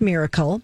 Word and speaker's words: MYRKL: It's called MYRKL. That's how you spell it. It's pronounced miracle.